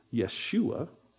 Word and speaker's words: Yeshua: So Joshua, so Jesus is that Greek-English transliteration of that word Joshua, or sometimes pronounced Yeshua. That would be more of a Hebrew pronunciation.